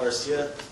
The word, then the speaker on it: Garcia: My name is Paul Garcia. Last name is spelled G-A-R-C-I-A.